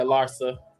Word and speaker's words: Larsa: Yeah, Larsa.